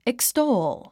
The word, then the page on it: extol: ikstóul; イクストーゥ